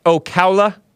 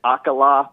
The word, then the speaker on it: Ocala: Is it pronounced Ocala? – Yeah, it's O-C-A-L-A.